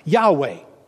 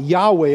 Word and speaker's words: Yahweh: In the Old Testament, when you see that word Lord, it's that Hebrew word, we're not exactly sure how to pronounce it, but they did say it at one time, Yahweh. King James had it as Jehovah, but they never actually said it that way.